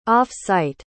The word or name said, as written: off-site